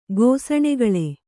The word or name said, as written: ಗೋಸಣೆಗಳೆ